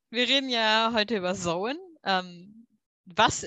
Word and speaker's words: Samhain: Ähm, für die, die das jetzt ein bisschen Probleme mit dem Begriff haben, ja, also geschrieben heißt es Samhain aber ausgesprochen wird es Sowen.